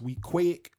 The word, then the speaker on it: Weequahic: It's pronounced Week Quick. Well, we say Week Way.